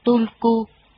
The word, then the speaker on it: Tulku: Chữ Hubruisku thường được viết theo cách phát âm là Tulku, nghĩa là hóa thân, chuyển sanh, là một cơ cấu truyền thừa riêng của Phật giáo Tây Tạng.